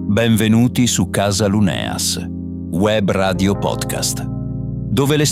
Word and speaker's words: Luneas: Cerchiamo di pronunciarlo bene, Luneas. – Esatto, Luneas.